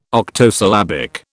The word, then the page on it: octosyllabic: British English